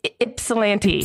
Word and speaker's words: Ypsilanti: And what, say that again, Ypsilanti? – Ypsilanti. It's spelled with a Y.